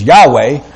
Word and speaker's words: Yahweh: Now actually the word is Yahweh, but the Jews feared the Lord so greatly and revered him so much that they would not even pronounce his name, so they used other syllables. And they put it to the consonants, and it sounded out Jehovah. Because they feared the Lord so much, they would not dare say the name Yahweh. That's his name.